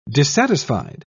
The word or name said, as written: dissatisfied